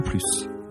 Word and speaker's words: plus: On prononce plus quand la phrase est négative. Je ne veux plus de café. Je n'en veux plus. Il n'y en a plus. Moi non plus. On prononce également plus dans une comparaison quand l'adjectif qui suit commence par une consonne.